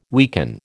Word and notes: weaken: American English

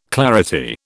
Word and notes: clarity: British English

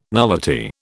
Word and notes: nullity: British English

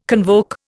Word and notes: convoke: Indian

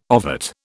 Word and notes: ovate: British English

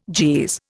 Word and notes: Jeez: Indian

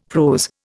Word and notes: prose: Indian

